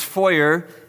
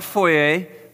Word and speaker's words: foyer: It's foyer, not foyer. Foyer sounds like a sophisticated, nice way to say it. It is, in fact, only wrong, so it's not sophisticated at all. Foyer is the correct pronunciation.